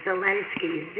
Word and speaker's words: Zalensi: My first name is Anita, A-N-N-I-T-A. You want my last name, Zelensky, Z-A-L-E-N-S-I.